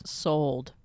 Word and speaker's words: soldes: What's the French word for sale that's on all the windows? – Sold. – Sold. Wait, how do you spell it? – S-O-L-D-E-S.